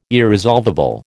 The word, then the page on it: irresolvable: American English